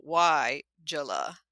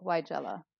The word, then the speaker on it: Weigela: But the spelling, if you're not familiar with it out there, is Weigela, and they have phonetically translated it as WY-JUH-LUH. So Weigela. – That's right. – How they're saying to pronounce it. – That capital letter tells you that your emphasis should be on that syllable, Weigela.